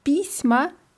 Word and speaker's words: письма: I pisma heißt Briefe mit Betonung auf i, das ist wichtig.